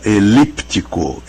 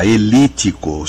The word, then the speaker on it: elíptico: Bom, então, começando, esse P, quando a gente escreve, a gente pronuncia elíptico, elíptico. E quando a gente não escreve, a gente não pronuncia, é óbvio, ele não está lá, elítico.